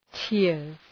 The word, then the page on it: tears: tıərz